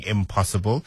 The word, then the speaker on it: I'm Possible: I keep on saying impossible, it's spelled I am apostrophe possible, so I'm possible, yeah.